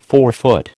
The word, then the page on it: forefoot: American English